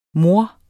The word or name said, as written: mor